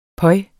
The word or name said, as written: pøj